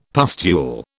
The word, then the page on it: pustule: British English